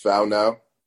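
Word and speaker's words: Whānau: Is it Wanau or is it something else? – So it's pronounced Faunau. – Faunau.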